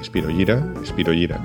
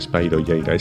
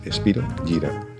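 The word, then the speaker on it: Spyro Gyra: que además se dice así, Spiro Gyra, latín, es el nombre de un protozoo, no se dice Spiro Gyra, es Spiro Gyra, latín.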